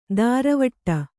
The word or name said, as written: ದಾರವಟ್ಟ